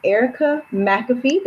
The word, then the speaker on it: Erica McAfee: And I am here with Erica McAfee.